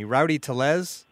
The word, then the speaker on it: Tellez: So, Jonathan, I'll start with one of the guys you were able to catch up with, and that was, and make sure I'm pronouncing this right, because it's just one name that always kills me, Rowdy Tellez. – Yep. – Is that right? Yay.